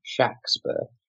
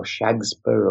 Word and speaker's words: Shakespeare: And then when you realize that, oh, but the man that we're told is Shakespeare, who spelled his name various ways, but was always with a hard A sound, Shakespeare. So it's Shakespeare or Shagspur, or it's spelled various ways in these only remaining six signatures. But nevertheless, it's never Shake, it's always Shack.